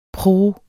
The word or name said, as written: pruh